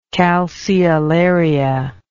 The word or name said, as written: calceolaria